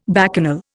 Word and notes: bacchanal: Indian

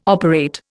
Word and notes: operate: Indian

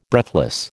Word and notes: breathless: American English

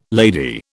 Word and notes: lady: British English

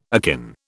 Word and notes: akin: British English

